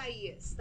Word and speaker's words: Isaias: We speak those languages. So, yes, it Isaias. That's see? How you pronounce the name of the system that we have currently in the Gulf. I'm Gulf Coast meteorologist. – She's who? Cope? – Gulf Coast meteorologist. – Oh, okay. – Isaias. – Well, Isaias. That's how you pronounce Isaias.